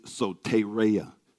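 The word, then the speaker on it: soteria (σωτηρία): You hear it most often said soteria, but the original, the actual pronunciation in the Greek is soteria.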